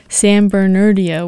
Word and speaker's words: San Bernardino: Starting off, I played San Bernardino Waltz.